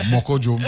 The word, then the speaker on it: Moko Jumbi: And actually, it's two words, but it's actually pronounced as one. – Right. – Moko Jumbi.